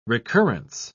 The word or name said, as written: recurrence